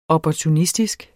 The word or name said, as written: opportunistisk